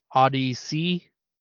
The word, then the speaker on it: ODY-C: It is Odyssey. That's spelled ODY-C.